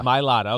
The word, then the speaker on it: Mailata: You know, Malata, he is out of the game this past game. I know I butchered his last name. So Jordan Mailata. – Mailata. Okay, I was close enough.